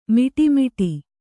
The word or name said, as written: ಮೋಡಾಮೋಡಿ